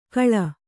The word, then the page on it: ಕಳ: kannada